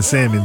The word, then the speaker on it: salmon: I think if you say, if you pronounce an L in draws, you pronounce the L in salmon too.